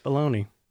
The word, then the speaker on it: Filoni: Yeah, you got Jon Favreau and Dave Filoni, I think is how you say his name. – Filoni. – Yeah.